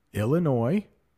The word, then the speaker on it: Illinois: Illinois, I know there is an S there, but it's pronounced Illinois.